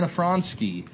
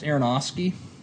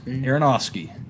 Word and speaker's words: Aronofsky: Darren Afronsky. It's actually pronounced Aronofsky. And Aronofsky.